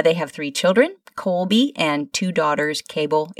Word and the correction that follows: Cabell: Is it Cable and Cassidy? – It's pronounced Cabell. – Cabell.